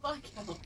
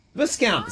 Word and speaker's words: Viscount: Viscount. – It's got an S in it. Viscount. It's like discount. Put it with a V. – It's like the S in disco. You don't pronounce it.